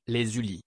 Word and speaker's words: Les Ulis: Les Ulis. – Les Ulis. – U-L-I-S, in France in 1977.